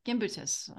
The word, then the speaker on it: Gimbutas: Uh, Gimbutas, Gimbutas. Um, I was saying it wrong until someone corrected me.